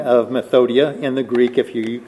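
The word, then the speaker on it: Methodia (μεθοδεία): Wiles, Greek, methodoia. Methodia. Got an extra syllable in there. Methodia.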